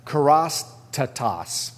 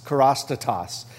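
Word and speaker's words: kerostatos (χρηστότης): The word for kindness in the New Testament is pronounced kerostatos, kerostatos. And it means, and it can be translated in different ways, like kindness, for example, generosity, goodness or moral goodness, integrity.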